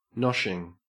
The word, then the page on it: noshing: Australian English